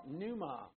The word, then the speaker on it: pneuma: And the human spirit in the Greek is called pneuma. The P is silent. It's P-N-E-U-M-A, pneuma.